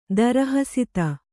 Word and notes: ದಾರುಹಸ್ತ: dāru hasta

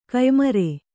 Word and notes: ಕಯ್ಮರೆ: Kannada

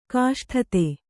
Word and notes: ಕಷ್ಟತೆ: kaṣṭate

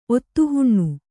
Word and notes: ಒತ್ತುಹುಣ್ಣು: Kannada

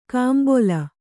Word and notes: ಕಂಬೊಲ: Kannada

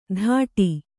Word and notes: ಧಾಟಿ: Kannada

